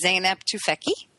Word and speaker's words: Zeynep Tufekci: It is Zeynep Tufekci.